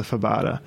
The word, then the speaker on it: fabada: So obviously, this meal would be incomplete without, how do you say it, fabada.